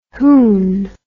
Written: hoon

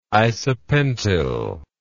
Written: isopentyl

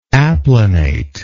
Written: applanate

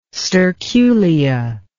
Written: sterculia

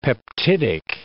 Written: peptidic

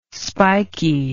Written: spiky

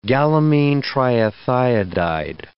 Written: gallamine triethiodide

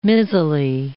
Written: mizzly

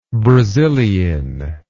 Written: brazilein

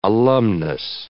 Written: alumnus

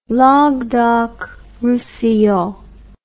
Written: languedoc roussillon

